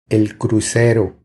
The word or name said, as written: El crucero